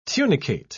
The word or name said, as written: tunicate